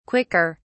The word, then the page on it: quicker: British English